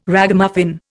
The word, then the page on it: Ragamuffin: Indian